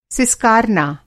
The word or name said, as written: सिसकारना